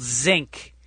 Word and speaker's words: Zinque: There is a little place on Abbot Kinney in Venice called Zinc. And it's spelled Z-I-N-Q-U-E.